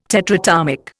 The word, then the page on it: tetratomic: Indian